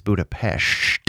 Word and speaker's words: Budapest: You know that I was just in Budapest, which is how the, the Hungarians pronounce it. It's Budapest.